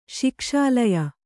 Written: ಶಿಕ್ಷಾಲಯ

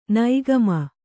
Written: ನಿಕಾಮ

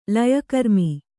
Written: ಲಯಕರ್ಮಿ

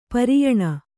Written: ಪರಿಯಾಣ

